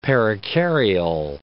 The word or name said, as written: perikaryal